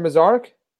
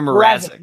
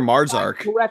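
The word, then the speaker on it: Mrázek: Isn't it Peter Mrazek? – Peter Mrazek. – Mrazek. I thought you called him Peter Marzark. – I corrected myself before I let you answer me there. Mrazek.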